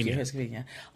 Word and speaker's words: Herzegovina: Herzegovina. – Herzegovina.